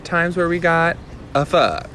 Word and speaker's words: pho: Which, by the way, is that really how you say it? – Pho. – Pho. – Yes. Instead of pho. – Yeah, that's what I thought it was. – Some people say pho. I think the Vietnamese pronunciation is pho.